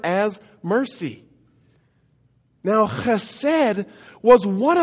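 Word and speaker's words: chesed (חסד): And it's pronounced chesed. Chesed. Say that with me. Chesed. Again, chesed.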